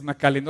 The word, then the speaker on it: McAllen: Entonces nos, cruzó la frontera y ellos viven del otro lado, que es Macallen. Ahora así lo dije bien. Sí, ok. Reynosa colinda con McAllen y,